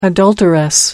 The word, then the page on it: adulteress: American English